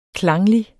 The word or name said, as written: klanglig